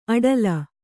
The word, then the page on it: ಅಡಲ: aḍala